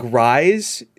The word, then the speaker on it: Ryes: So, but it's spelled like, almost like Rise. See, it's like R-Y-E-S.